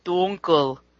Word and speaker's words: Dunkel: Dunkel. – I believe it's pronounced dunkel. – Bugger. – Pronounced dunkel.